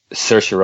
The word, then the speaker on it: Saoirse: Saoirse Ronan. – Saoirse Ronan, yeah, I always get the first name. Saoirse Ronan.